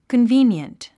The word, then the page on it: convenient: kənvíːnjənt; コンビィニエント